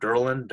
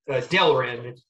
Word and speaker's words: Delrin: How do you say that word? Derlin? Derlin? – It's a Delrin. It's basically plastic. It's like a really tough, durable plastic. It's called Delrin.